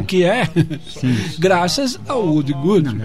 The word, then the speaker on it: Woody Guthrie: Como é que fala isso? – Woody Guthrie.